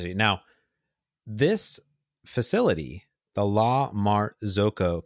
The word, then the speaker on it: La Marzocco: There's a La Marzocca. How do you spell it? La Marzocca. This is the Italian, okay. Florence-based Italian espresso maker. La Marzocco.